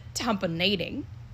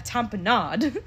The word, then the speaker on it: tamponade: But he pronounced it tamponading. And that's wrong. I looked it up to verify. Because I was like, wait, Grey's Anatomy calls that a tamponade.